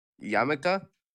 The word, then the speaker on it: yarmulke: Wait, pronounce it again. – Yarmulke? – Yarmulke? – Yeah, like yam.